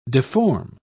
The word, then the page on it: difform: British English